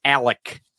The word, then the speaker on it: Alec: But here's the moment where Alec, and it's spelled Alex, but it's Alec, and it's spelled Murdaugh, but it's Murdaugh.